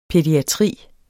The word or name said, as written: pædiatri